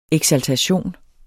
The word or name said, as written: eksaltation